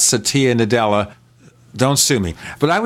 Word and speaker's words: Satya Nadella: Well, speaking of kicking around, we're going to have more of Bill Gates, because the new CEO, and I had to look up the New York Times for how to pronounce this. It's Satya Nadella. – Satya Nadella.